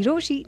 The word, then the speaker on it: Rochie: मेरा नाम है मिर्ची रोशी, नाम के स्पेलिंग है ROCHIE and I super love you.